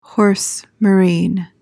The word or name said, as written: horse marine